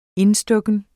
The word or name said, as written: indstukken